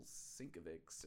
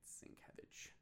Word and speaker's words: Sienkiewicz: we were calling him Bill Sienkiewicz or something like that. You know what I mean? And it's Sienkiewicz.